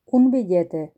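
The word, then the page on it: billete: Spain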